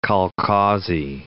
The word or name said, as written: kolkhozy